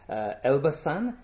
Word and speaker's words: Elbasan: Elbasan, is that correct pronunciation? – Yes.